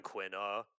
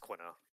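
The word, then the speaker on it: Quina: I think it's just Quinna.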